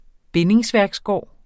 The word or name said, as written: bind